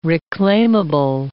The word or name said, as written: reclaimable